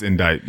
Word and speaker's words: indict: Yeah, it's spelled with a CT, but it's just pronounced indict.